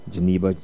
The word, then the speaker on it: Djenaba: So Geneva Joseph, and spelled D-J-E-N-A-B-A, and I remember seeing the Django and D-J-A, and none of the D is silent, so easy.